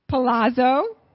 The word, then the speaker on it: Palazzo: Palazzo, palazzo. The Z's say T in Italian. Palazzo Creations. Yeah, oh, and Melissa made this little graphic that we'll be displaying with our palace and our creations. Palazzo.